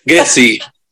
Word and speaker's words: Ghezzi: Ghezzi.